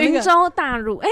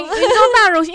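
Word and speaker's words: 雲州大儒: 云州大儒哎。对对，云州大儒，因为他是儒侠史艳文啊。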